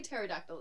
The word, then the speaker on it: pterodactyl: Well, you say pterodactyl, it's a silent P.